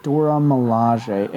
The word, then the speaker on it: Dora Milaje: Dora Milaje.